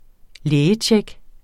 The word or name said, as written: lægetjek